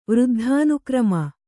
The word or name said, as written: ವೃದ್ಧಾನುಕ್ರಮ